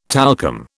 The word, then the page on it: talcum: British English